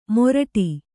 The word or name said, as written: ಮೊರಡಿ